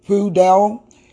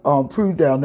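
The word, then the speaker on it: Pekudei: It's called Pudel, and it's spelled P-K-U-D-E-I. It's called Pudel. Let me spell it again. P-apostrophe-K-U-D-E-I. And it means accountings of.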